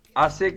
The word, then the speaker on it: Hase: Asé, que se escribe H-A-S-E. Ahí la H suena como J. Por eso es Itá Asé. La H en guaraní es sonora, no es como el, como en el español, que la H es muda.